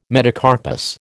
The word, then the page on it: metacarpus: American English